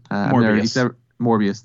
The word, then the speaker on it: Morbius: and I'm not a big Mobius guy. Morbius, thank you.